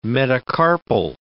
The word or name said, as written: metacarpal